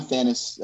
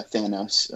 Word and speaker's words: Thanos: No, I mean, yeah, I'm a Thanos. Uh, Thanos. I said it wrong, of course.